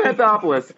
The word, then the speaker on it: Anthopoulos: If I'm Anthopoulos, I'm going to butcher that every time I say it. – That was good. – If I'm Anthopoulos, I am.